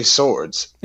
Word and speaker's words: Sowards: You know how to say swords. There's two of them. You know how to say it. They're weapons, multiple weapons. You've been saying swords since you were like 3 years old.